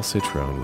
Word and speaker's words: Citron: Paul Citron rhymes with bone.